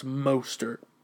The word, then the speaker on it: Mostert: I'm going to go with Morsett. Mostert, excuse me. I say his name wrong all the time. It's Mostert.